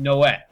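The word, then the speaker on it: Noël: Like I said, I listened to a French YouTube video where he was saying that phrase, and it sounded like he was pronouncing it Noé, like there was no L sound. It was just, you know, if I was going to spell it phonetically, it would just be N-O-E.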